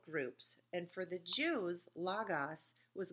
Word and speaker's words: logos: It's L-O-G-O-S. It looks like logos. And anytime someone says it that way, I cringe a little bit because I'm really a stickler on pronunciation. So say it with me. Logos.